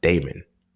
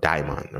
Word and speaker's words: daemon: But when you actually break down demon, the word demon, you take it back to its origin, which is Greek, and it was originally pronounced daemon, you know, daimon, or it was spelled D-A-E-M-O-N.